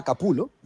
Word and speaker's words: Capullo: Exacto, podemos pasar a Greg Capullo, Capulo. Eh, el, Capullo, tío, oye, Capullo. Claro. que si, mal no creo que lo he escuchado es Capulo, ¿verdad? Capulo, es la pronunciación correcta.